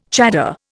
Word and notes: chador: Indian

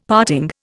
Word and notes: parting: Indian

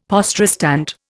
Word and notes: poste restante: Indian

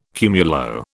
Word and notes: cumulo: British English

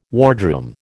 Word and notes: wardroom: American English

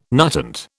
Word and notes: nutant: British English

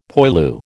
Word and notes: poilu: American English

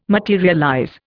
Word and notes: materialize: Indian

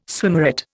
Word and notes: swimmeret: Indian

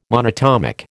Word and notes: monatomic: American English

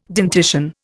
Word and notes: dentition: Indian

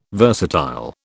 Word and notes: versatile: British English

